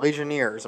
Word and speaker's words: Legionnaires: All right, so 12 cases of Legionnaires.